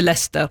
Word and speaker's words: Leicester: Kjekt å høre på, men det heter Leicester.